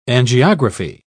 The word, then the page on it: angiography: British English